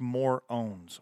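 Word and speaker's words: Morönes: The next band is The Morones, and I think I'm pronouncing that right, Morones. Looks like morons, but it has that umlaut over the second O, so I think that's what it is. They've put on their website pronounced More Owns or on their social medias.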